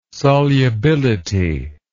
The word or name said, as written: solubility